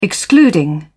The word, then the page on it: excluding: British English